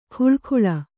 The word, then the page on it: ಖುಳಖುಳ: khuḷakhuḷa